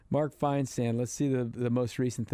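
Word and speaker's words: Feinsand: Mark Farisand. – Mark Finesand. – Finesand. Said his name wrong, yeah.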